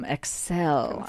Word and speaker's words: Accell: Yes, from Excel. I think I'm saying it correctly. Yes. A-C-C-E-L-L.